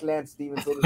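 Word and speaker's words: Lantz Stevenson: Not him, but a guy who pronounces his name like Lance Stevenson. L-A-N-T-Z Stevenson.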